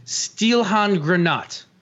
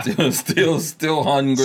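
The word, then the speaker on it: Stielhandgranate: It's a Steelhandgranat. – Yeah. – Steelhandgranat. – Steelhandgranat. You gotta say it harsh.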